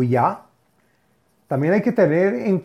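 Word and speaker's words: Ya (יה): Las letras o palabras son la letra Yud y la letra Hey, que se pronuncia Ya y que es la primera parte del nombre de Hashem.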